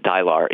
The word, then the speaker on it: Dylar: So what role does Dylar, is that how you pronounce it, the drug in White Noise? What's that about? – I would have always said it, Dylar. You know, it doesn't come with a pronunciation guide, but it sounds right.